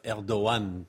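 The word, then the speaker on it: Erdoğan: Voilà, vous comprenez pourquoi oui, voilà, il faudrait aussi dire euh, Erdogan, parce que le G n'est pas tout à fait prononcé.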